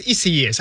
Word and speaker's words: Isaias: It's Isaias or Isaias. No, it's Isaias. I just heard it.